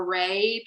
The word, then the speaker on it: Reagh: It's Eleanor Ray, but Ray is spelled R E A G H.